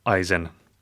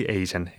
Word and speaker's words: Eisen: 1746 sai Torma koguduse õpetajaks Johann Georg Eisen, või Eisen eestipäraselt hääldates.